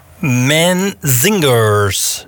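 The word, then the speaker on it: Menzingers: En daarvoor, ik heb het even opgezocht, de Manzingers, zo spreek je het uit.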